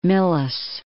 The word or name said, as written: Milles